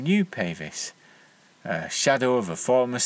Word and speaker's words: Pavis: I've since learnt that this is more accurately pronounced Pavis, but I've always used the long vowel sound because I assumed that it was related to pavement, which is the British name for sidewalk.